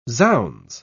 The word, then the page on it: zounds: British English